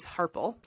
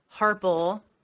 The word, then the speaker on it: Harpel: So my married last name is Harpel, and it's spelled H-A-R-P-E-L. I think what throws people off is the E-L instead of L-E, because you would Harpel, you would think it would be L-E, and a lot of people pronounce it Harpel,